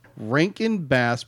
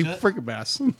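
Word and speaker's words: Rankin/Bass: Its production was and I'm going to get this right because I always say it wrong. Rankin Bass Productions because I always say Bass. For some reason, I always say Franken Bass.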